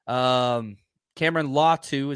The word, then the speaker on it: Cameron Latu: Cameron Latu is how it's pronounced.